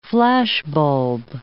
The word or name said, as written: flashbulb